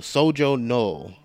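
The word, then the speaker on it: Shojo Null: Yeah, Sojo Null.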